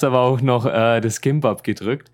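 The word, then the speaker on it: Kimbab (김밥): Es war an dem Tag, mittags, als wir Gimbab gegessen haben. Also eigentlich Kimbab ausgesprochen. Ich, ich sage immer bloß ein G am Anfang.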